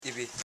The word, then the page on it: ibi: i.bi